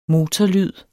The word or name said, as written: motorlyd